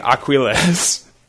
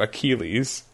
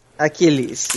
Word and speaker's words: Achilles: I was saying Aquiles, which I know doesn't make any sense. Like, that's definitely not how it's pronounced, but it's going to take me a second to get to Achilles.